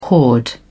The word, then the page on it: hoard: British English